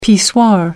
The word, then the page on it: pissoir: American English